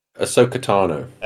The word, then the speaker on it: Ahsoka Tano: Ahsoka Katano. Is that how you say it? – Ahsoka Tano.